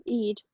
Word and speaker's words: Eid: Eid, is that how you pronounce it? – Yeah.